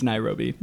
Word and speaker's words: Nairobi: It's Nairobi, but yeah.